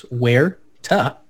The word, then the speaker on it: Hirota: Well, don't pronounce the H. Just where ta.